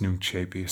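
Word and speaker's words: Nunc Coepi: And again, Nuncepi is spelled N-U-N-C, and then the second word is C-O-E. P-I. That's pronounced Nuncepi.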